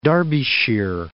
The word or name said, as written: Derbyshire